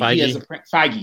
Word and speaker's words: Feige: Kevin Feige, I'm probably mispronouncing his name. – Feige.